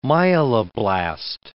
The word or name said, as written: myeloblast